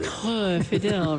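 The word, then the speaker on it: Coeur fidèle: That's got to get a little guttural. Cor Fidel. – Cor Fidel.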